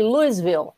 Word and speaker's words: Louisville: você é de Louisville, você pronuncia Louisville.